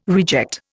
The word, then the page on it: reject: Indian